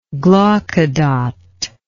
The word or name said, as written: glaucodot